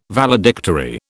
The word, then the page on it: Valedictory: British English